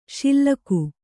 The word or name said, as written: ಶಿಲಕು